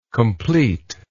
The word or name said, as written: completer